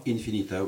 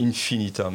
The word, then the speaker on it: Infinita: c'est bien ça ? – Infinita, oui. – Infinita, merci de me corriger dans la prononciation.